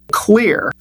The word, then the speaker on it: Xlear: The toothpaste we recommend on the program is Spry, and that's spelled S-P-R-Y, and it's by Clear, and that's spelled X-L-E-A-R.